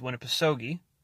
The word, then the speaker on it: Winnipesaukee: properly pronounced Winnipesaukee.